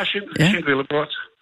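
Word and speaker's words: Sint-Willebrord: Du bor i det, der hedder, nu skal jeg ligesom, jeg kan udtale det korrekt, Sint-Willebrod. – Helt rigtigt. – Er det sådan, man siger det? Okay. – Ja, Sint-Willebrod.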